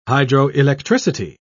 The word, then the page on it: hydroelectricity: British English